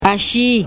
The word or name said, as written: ashii